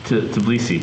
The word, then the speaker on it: Tbilisi: Tbilisi.